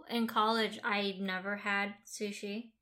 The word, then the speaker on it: sushi: So I used to say shushi, mm-hmm. – like as in like a shoe. – Mm-hmm. Who's a she? – And who's a she? Sushi.